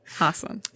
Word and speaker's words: Hasan: Uh, yeah, it was on Ellen because she said his name wrong, and he's like, "It's actually Hasan."